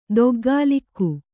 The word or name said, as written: ದೊಗ್ಗಾಲಿಕ್ಕು